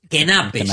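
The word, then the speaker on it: Knappe: Aparecía con K por delante. O debería decir Kenappe, si queremos pronunciar perfectamente. Sí, Kenappe.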